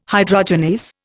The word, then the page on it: hydrogenase: Indian